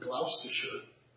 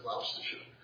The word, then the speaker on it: Gloucestershire: That's how you pronounce that word, Gloucestershire. I don't know how it ends up spelled the way it is, but it's Gloucestershire.